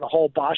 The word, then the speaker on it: Holbox: We met some backpackers when we were on Holbox, and it's a funny name. It's pronounced Holbox, but it's spelled Holbox. – That's right. – The X is pronounced shush.